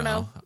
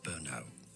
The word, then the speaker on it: Brno: So locally in Czech, it's said as Brno. Brno no. – Now in English, therefore, it's usually said as Burno, Burno, Burno.